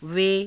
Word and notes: ve: Hindi

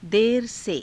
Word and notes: dera_se: hindi

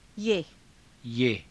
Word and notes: yaha: Hindi

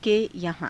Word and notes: ke yahaa~: Hindi

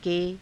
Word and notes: ke: Hindi

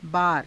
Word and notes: baara: Hindi

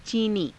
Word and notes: ciinii: hindi

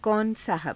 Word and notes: kauna saahaba: Hindi

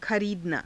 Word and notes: khariidanaa: Hindi

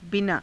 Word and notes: binaa: hindi

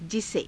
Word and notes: jise: Hindi

